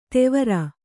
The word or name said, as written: ದೇವರ